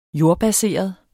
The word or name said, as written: jordbaseret